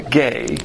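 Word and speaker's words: gay (γῆ): And we're not talking about the gay. That's how it's pronounced.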